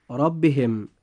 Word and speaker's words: ربهم: نجد أن الراء في كلمة ربهم جاءت مفتوحة، فيجب أن تفخم هكذا: ربهم.